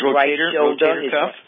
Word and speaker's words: rotator cuff: I think it's pronounced rotacup on his rotator cuff. Rotator cuff. – Yes. How do you pronounce that again, Dr. Wallach? – Rotator, like rotation, rotator? – Rotator.